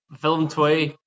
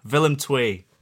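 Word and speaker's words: Willem II: Willem 2. – Willem 2.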